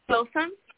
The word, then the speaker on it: Filsan: Filsan.